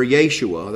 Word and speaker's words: Yeshua: On the eve of the Passover, Yeshua, that's the Jewish way to pronounce Jesus, was hanged for 40 days.